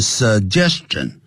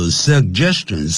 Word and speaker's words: suggestion: 比如说 John's suggestion suggestion，因为有两个 G，所以我们常常中文人呢会念成 suggestion，可是老美他们在练的时候 suggestion sug 先停顿一下 suggestion 这样念法啊。